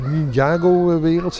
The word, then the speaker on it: Ninjago-wereld: We staan bij de Ninjago-wereld, zeg ik dat goed, Sibo? – Ja, dat is correct.